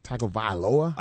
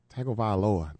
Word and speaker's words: Tagovailoa: Tagovailoa. – I think you nailed it. – Is that what it is? – I think that was perfect. – Tagovailoa.